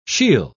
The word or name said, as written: she'll